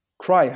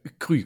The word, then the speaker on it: Kry: Cry, habe ich es richtig ausgesprochen? – Äh, Krü, glaube ich.